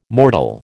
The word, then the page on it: mortal: American English